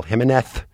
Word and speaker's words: Jimenez: Miguel Angel Jimenez. We got the memo that we had to pronounce the Z as a TH.